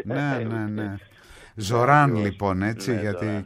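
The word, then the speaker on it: Zohran: Ναι, βεβαίως, εννοείται ότι ψήφισα τον Ζωράν, είναι το σωστό. – Το H δεν προφέρεται, γιατί το έχω διαβάσει Ζόχραν, το έχω διαβάσει Ζωχράν, το έχω διαβάσει όπως να είναι.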